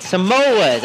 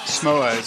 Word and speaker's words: Samoas: Samoas. I think that's how you say it. Oh, some. Oh, yes. Samoas.